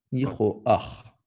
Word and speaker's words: Nihroach: Nihroha agréable à HM. – Nihroach l'Hachem. – Quand tu as un patachet à la fin d'un mot en hébreu, ça se prononce ach. – Ach. Nihroach.